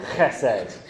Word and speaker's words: chesed: You've got to have a lot of phlegm in you. Chesed. Um, if, if um, the person that you're, you're speaking to isn't drenched a little bit, then you're saying it wrong.